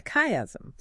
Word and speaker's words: chiasm: Which is this structure called a chiasm. – A chiasm. – So it's spelled C-H-I-A-S-M.